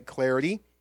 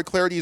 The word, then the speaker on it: Clardy: Number one, you can follow me on Twitter at Troy Clarity. At Troy Clarity is the way to go. Last name is spelled C-L-A-R-D-Y.